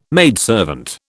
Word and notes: maidservant: British English

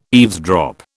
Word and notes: eavesdrop: British English

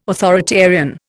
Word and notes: authoritarian: Indian

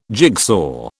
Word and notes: jigsaw: British English

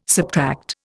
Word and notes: subtract: Indian

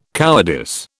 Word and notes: cowardice: British English

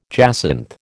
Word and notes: jacinth: American English

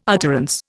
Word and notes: utterance: Indian

